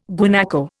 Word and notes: guanaco: Indian

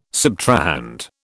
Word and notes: subtrahend: British English

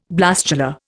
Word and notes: blastula: Indian

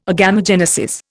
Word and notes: agamogenesis: Indian

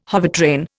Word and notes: hovertrain: Indian

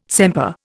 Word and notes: sempre: Indian

